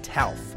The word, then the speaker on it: TALF: Enter the federal government last November with a program called TALF. It rhymes with ALF, I know, but the only alien life forms here are the asset-backed securities.